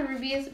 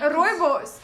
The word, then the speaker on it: Rooibos: Wait, so they cut the rubies. Rubies? How do you say it? – What are we talking about? – The tea. I'm like. – Rooibos.